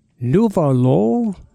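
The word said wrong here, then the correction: Nuevayol: Nueva Yol.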